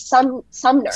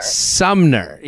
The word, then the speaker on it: Sumner: It's Summer. And then I was like, no, I literally can't read. Her name is Sumner. – Sumner, yes.